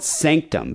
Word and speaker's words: SNCTM: It's called Sanctum, but it's spelled S-N-C-T-M.